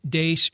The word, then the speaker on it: Deace: You can also like us on Facebook, follow us on Twitter, at Steve Deace Show. Last name is spelled D-E-A-C-E.